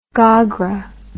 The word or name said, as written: gogra